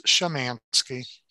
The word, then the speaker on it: Szymanski: It's close. It's Szymanski. – Szymanski. – Like S-H-A.